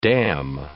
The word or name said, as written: damn